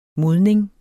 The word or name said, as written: modning